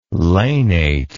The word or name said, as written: lanate